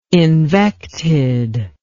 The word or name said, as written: invected